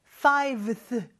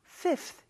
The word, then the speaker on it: fifth: 요거는 이제 발음상 fiveth 너무 어려워서 fifth 이렇게 된 거예요.